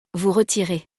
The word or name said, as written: vous retirez